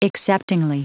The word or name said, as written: acceptingly